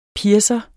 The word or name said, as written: piercer